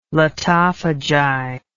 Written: lotophagi

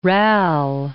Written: rale

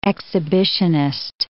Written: exhibitionist

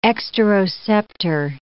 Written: exteroceptor

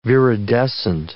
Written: viridescent

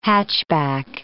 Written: hatchback

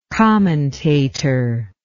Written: commentatorially

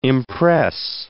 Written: impress

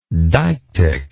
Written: deictically

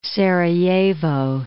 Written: Sarajevo

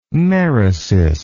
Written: merisis

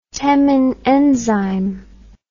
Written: Temin enzyme

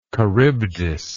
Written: charybdian

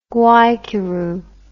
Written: Guaicuru

